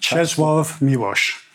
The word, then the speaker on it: Czesław Miłosz: Czesław Miłosz.